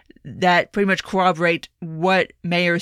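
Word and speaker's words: Meijer: And from reporting that has come out, Representative Mayer, it's not Meyer, it's Mayer.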